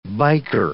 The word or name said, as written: biker